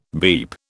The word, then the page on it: beep: British English